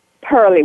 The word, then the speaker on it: Pearly: Pearly with a P. – And how do you pronounce your last name? – Lusan, L-U-S-A-N, Lusan.